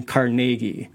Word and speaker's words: Carnegie: Now, in Scotland, they would have pronounced the surname Carnegie. – Right. – But like many Americans, you know, they change pronunciations or spellings, in this case, just the pronunciation. So they go from Carnegie to Carnegie.